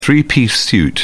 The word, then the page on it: three-piece suit: British English